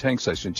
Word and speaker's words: TANGCISON: If you need a home video LED video projector, Tangsison, which I believe it looks like that's how it's pronounced, Tangsison, T-A-N-G-C-I-S-O-N, has their home projector 3300.